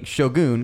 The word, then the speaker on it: Shogun: See, you, sir, are pronouncing it the American way. We have been praised by a Japanese-speaking man for actually saying it correctly. So we're going to keep saying Shogun because that is the correct pronunciation of the word.